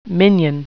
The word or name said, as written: minion